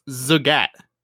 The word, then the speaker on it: Zagat: How do you pronounce Zagat? – Zagat. – It's Zagat. – Oh, I was close.